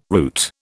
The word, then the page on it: route: British English